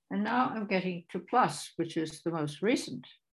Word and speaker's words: plus: It is attested in Koha as the mathematical concept and also in quotations from French, where the word plus, no S pronounced there, plus means more.